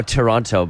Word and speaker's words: Toronto: I say it now, Toronto, but before I came over here, it was Toronto. – Yeah, no one pronounces the T over there. It's like Toronto.